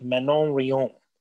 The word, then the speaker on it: Manon Rhéaume: I know we have a lot of listeners in Canada, so guys, please let me have it because I'm gonna say this wrong because it's a French name, but it's like Manon Rhéaume was her name. That was well done.